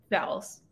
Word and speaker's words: Vowles: And James Val, how do you say his last name? – Vowels. – Just as it's spelled.